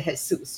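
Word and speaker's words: Jesús: 就是其实是西班牙文发音是 Jesus，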